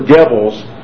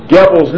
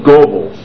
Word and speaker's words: Goebbels: I pronounce Dr. Goebbels, it's spelled G-O-E-B-B-E-L-S. Now, if you know your German grammar, G-O-E is the same as writing G-O with an umlaut over the O, taking away the E, and B-B-E-L-S.